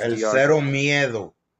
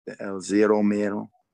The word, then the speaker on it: El Zero Miedo: El Zero Miedo. – Is that how you say it? – Yeah. – El Zero Miedo.